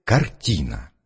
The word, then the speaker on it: картина: Определи-ка на слух главный слог в слове «картина». – Картина. По-моему, слог «ти». – Почему ты так решил? – Потому что, когда мы говорим «картина», мы на звуке «и» голос делаем громче и слог выделяем.